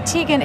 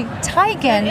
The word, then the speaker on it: Teigen: That your name is not Chrissy Teigen, it's actually Tygan, and that everyone's been pronouncing it.